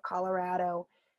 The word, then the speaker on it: Colorado: As you can probably tell as you're listening, I both say Colorado with a strong ah sound. – Right. – I think that's a short A is what I'm learning in the first grade class. Short sure. A, like cat, at, cat.